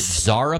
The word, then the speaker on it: Zara: Is it Zara pants? Have you ever heard of this? Z-A-R-A? Is that how you say it? – Yes.